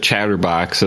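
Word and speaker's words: Chtrbox: It's called, you pronounce it Chatterbox. It's like shortened.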